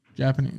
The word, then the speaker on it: Japanese: Japanese. – That's why I don't speak it. – Japanese. Yeah, I don't speak Japanese either. So you're just putting emphasis on the wrong syllable.